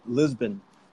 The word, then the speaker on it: Lisbon: That's how you say it, right? Lisbon? – Lisbon. – Lisbon. – Or for the locals, it's Lisboa.